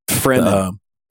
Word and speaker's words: Fremen: Fremen. – The Fremen. And I'm like, okay. – Think of it like this. They are free men, right? They are not. The free men, Fremen.